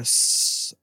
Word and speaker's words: Saufkumpan: The first one is Der Saftkampen.